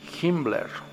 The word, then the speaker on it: Himmler: Hace rato han de haber escuchado que pronuncié Himmler. Así era como, así también se, se, hacía llamar, era conocido. Hess o Hitler, ¿ok? No me equivoqué, así dice.